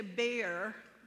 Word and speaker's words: Beer: He didn't think so, but apparently there are more, and they say bear, even though it's spelled beer.